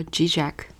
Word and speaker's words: Žižek: 嗯，像我们或者在英文世界的人，一般就念作 G Jack 就好。